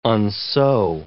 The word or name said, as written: unsew